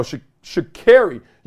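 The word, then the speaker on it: Sha'Carri: Is Shakari or Shakari? I see two R's. I ain't never said care. It's double R's up in here. She got Rolls Royce in her name.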